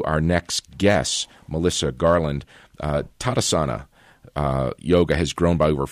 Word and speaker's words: Tadasana: Okay, first of all, tell us how to pronounce Tadasana.